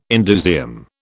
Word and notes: indusium: British English

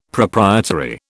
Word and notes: proprietary: British English

